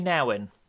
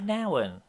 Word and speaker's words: Nouwen: which is Return of the Prodigal by Henri Nouwen. – Nouwen. It's pronounced Henri Nouwen. Everybody gets it wrong.